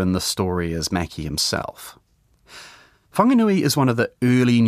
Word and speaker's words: Whanganui: The name's since been corrected to Whanganui, and that's the pronunciation we're using in this podcast, although Pākehā at the time would have spelled it without the H.